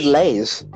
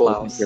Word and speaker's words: Laos: I thought you said Lay's. – Yeah, because that's how it's spelled. It's Laos, yeah. – I thought it was spelled... Okay, fair enough. – L-A-I-S. – No, it's L-A-O-S.